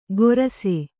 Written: ಗೊರಸೆ

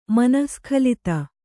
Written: ಮನಃಸ್ಖಲಿತ

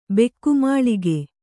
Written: ಬೆಕ್ಕುಮಾಳಿಗೆ

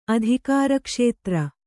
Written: ಅಧಿಕಾರಕ್ಷೇತ್ರ

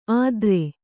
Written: ಆದ್ರೆ

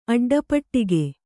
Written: ಅಡ್ಡಪೆಟ್ಟಿಗೆ

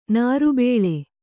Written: ನರಬಲಿ

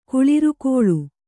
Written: ಕುಳಿರ್ಕೊಳ್

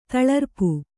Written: ತಳರ್ಪು

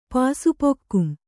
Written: ಪಾಸುಪೊಕ್ಕುಂ

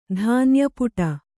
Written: ಧಾನ್ಯಪುಟ

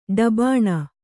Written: ಡಬಾಣ

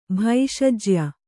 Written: ಭೈಷಜ್ಯ

